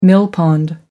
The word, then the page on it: millpond: American English